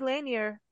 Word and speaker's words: Lanier: We say Lanier, so I don't know if that's. But that's how it's said around here.